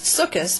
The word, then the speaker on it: Sukkot: Sukkot, pronounced Sukkot by Ashkenazi Jews, is a biblical Jewish holiday celebrated on the 15th day of the month of Tishrei, between late September and late October.